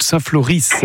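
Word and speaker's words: Saint-Floris: Donc, c'est, on dit Saint-Flory, hein, on ne prononce pas le S, on est bien d'accord. – Si, on prononce bien le S. – D'accord, j'avais un doute. Saint-Flory, près d'Hazebrouck, en présence donc de groupes très sympas, Vertigo et Atypique.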